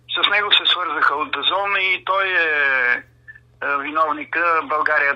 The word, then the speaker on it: DAZN: Това нещо да, ново за първи път матч от такава величина ние ще следим онлайн чрез платформата DAZN. Правилно ли я произнасям? – DAZN, да. Иначе и казват DAZN. – DAZN, DAZN, да. DAZN така е популярно. – Е правилното произношение, да.